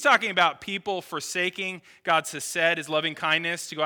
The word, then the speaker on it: hesed: That word steadfast love is a Hebrew word that's pronounced hesed.